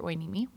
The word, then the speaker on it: Hueneme: My parents, who are Oregon natives, were living in Port Hueneme. My dad was in the Navy. – He was where? Port Wait? – Port Hueneme. It's spelled really weird, too. It's like H-U-E-N-E-M-E or something like that.